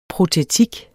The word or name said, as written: protetik